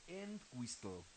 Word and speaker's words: Entwistle: Sí, John Entwistle, como de Castle.